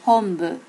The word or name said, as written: hombu